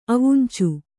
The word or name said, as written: ಅವುಂಚು